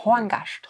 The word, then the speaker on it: Horngast: die Aussprache ist gut. Horngast.